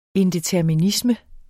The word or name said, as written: indeterminisme